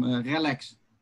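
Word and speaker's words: RELX: Relax.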